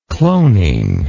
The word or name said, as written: cloning